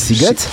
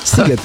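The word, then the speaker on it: Sziget: wierzysz, że pisze się Siget, a wymawia się Siget? Siget.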